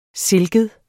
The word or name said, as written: silket